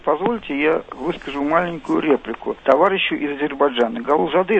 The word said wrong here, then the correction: Гулузаде: если не ошибаюсь, да? Правильно сказал? – Да, ну, немножко вы выговорили именно с московским, я бы так сказал, акцентом. Вафа Мирзович Гулузаде, правильно? – Ну, ради бога, извините, пожалуйста, не хотел обидеть.